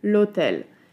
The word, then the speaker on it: hôtel: histoire czy hotel, gdzie powiemy odpowiednio l'histoire i l'hôtel. Dzieje się tak, ponieważ w wyrazach histoire i hotel mamy tak zwane h muet, czyli h nieme, a w przypadku n jest to h aspiré, czyli h przydechowe.